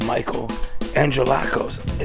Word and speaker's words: Angelakos: I'm hanging out right now with Michael Angelakos, is how it's pronounced. – That's correct, yeah.